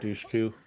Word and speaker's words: Dushku: Dushku.